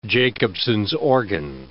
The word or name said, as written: Jacobson's organ